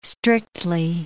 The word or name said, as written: strictly